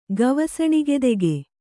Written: ಗವಸಣಿಗೆದೆಗೆ

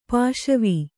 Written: ಪಸಿವು

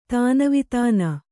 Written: ತನಿವದನು

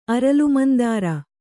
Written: ಅರಲುಮಂದಾರ

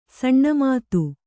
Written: ಷಣ್ಮತ

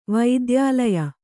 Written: ವೈದ್ಯಾಲಯ

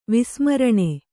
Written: ವಿಸ್ಮರಣೆ

